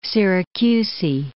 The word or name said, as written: Syracusae